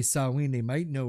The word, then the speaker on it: Samhain: the way it's pronounced, Samhain. So sow as in piggy.